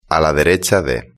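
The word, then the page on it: a la derecha de: Spain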